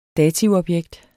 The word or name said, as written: dativobjekt